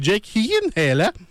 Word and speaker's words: Jake Gyllenhaal: Le film Life qui met en vedette Jake Gyllenhaal, où la bonne prononciation de son nom, c'est Jake Gyllenhaal.